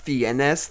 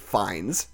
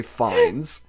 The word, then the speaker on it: Fiennes: Apparently his name is Ralph Fiennes. Whatever. – You fucked up. – It's spelled in a funky French way. It looks like Fiennes. Apparently you just say Fiennes.